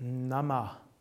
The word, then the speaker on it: Namah: Dann folgt Namah. Die A's sind beide kurz.